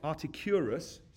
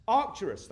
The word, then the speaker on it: Arcturus: And this is another amazing thing, because with the naked eye, in fact, right up until 1971, it was thought that Articus, Arcturus, sorry, someone help? Arcturus, thank you, brother. Arcturus, glad we got that corrected.